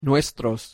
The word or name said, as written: nuestros